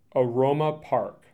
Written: Aroma Park

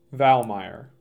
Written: Valmeyer

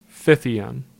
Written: Fithian

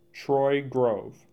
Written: Troy Grove

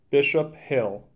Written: Bishop Hill

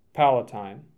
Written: Palatine

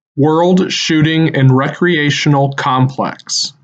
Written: World Shooting and Recreational Complex

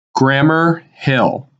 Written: Grammer Hill

